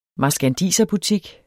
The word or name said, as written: marskandiserbutik